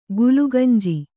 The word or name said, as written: ಗುಲಗಂಜಿ